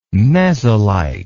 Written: mesolite

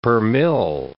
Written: per mill

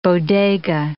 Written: bodega

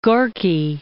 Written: Gorky

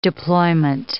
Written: deployment

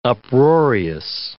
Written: uproarious

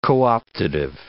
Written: co-optative

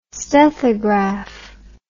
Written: stethograph

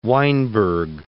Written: Weinberg

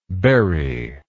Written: burying

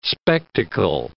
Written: spectacle

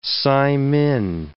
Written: saimin

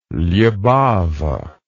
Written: libava

